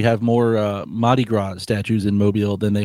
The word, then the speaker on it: Mardi Gras: So going back to what I was saying, first of all, in Mobile, they call it Mardi Gras. They don't pronounce the R. It's like pronounced like an H.